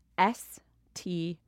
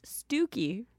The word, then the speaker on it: Stiffkey: Okay, so it looks like it's pronounced stiff key, but it clearly isn't. So what shall I say? Stucky. – Super close, actually. – Oh, really? – It's Stooky.